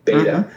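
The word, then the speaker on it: b8ta: There's another store chain, a startup store chain called Beta, B, and it's spelled B and then the number 8TA, Beta.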